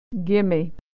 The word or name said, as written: gimme